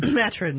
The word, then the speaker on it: Maturin: the Maturin? – Maturin, yes.